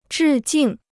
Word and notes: 致敬: zhì jìng

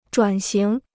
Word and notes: 转型: zhuǎn xíng

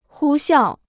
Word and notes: 呼啸: hū xiào

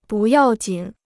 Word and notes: 不要紧: bù yào jǐn

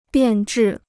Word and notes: 变质: biàn zhì

